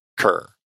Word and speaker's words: Kerr: And it is Deborah Kerr. I said it right.